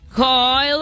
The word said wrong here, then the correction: Guy: Guy Lafleur, Tony Espósito y también estaba Brad Park, que estuvieron entre los integrantes de aquella generación.